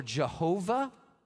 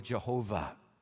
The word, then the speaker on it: Jehovah: It's YHWH or YHVH. It could be pronounced either way, Yahweh or Jehovah, one of those.